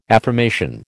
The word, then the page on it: affirmation: American English